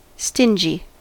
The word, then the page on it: stingy: American English